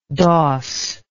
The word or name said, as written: dos